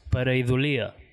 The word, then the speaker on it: pareidolia: Però ho vam demanar a un... erudit lingüista amic nostre que mos va dir que ho havien de pronunciar pareidolia, ¿vale?